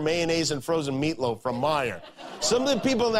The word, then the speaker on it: Meijer: A guy who spells his name M-E-I-J-E-R, but they pronounce it Meyer.